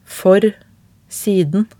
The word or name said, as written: for siden